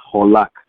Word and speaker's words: halak: It's spelled H-A-L-A-K, and the phonetic spelling is holak.